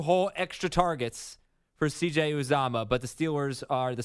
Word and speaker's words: Uzomah: And last thing, if you're desperate for a tight end, CJ Uzomah is 26% owned. – Oh, you got to say it right. – I didn't. – Uzomah. – It's Uzomah? – Uzomah.